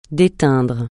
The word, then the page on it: déteindre: francais